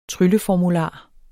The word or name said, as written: trylleformular